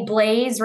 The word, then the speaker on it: Blais: Uh, my Instagram is Remax Brittany Blais right now. My last name is spelled B L A I S. Um, French Canadian again. we'll pronounce it with a Z still.